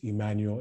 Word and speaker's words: Emmanuel: And so my name on Instagram is Emmanuel Enime,